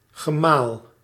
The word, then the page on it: husband: /ˈhʌz.bənd/